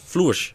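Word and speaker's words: Flörsch: Flu hoe, hoe spreek je het uit? Floers.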